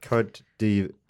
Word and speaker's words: Côte d'Ivoire: How do you say that the French way? Côte d'Ivoire.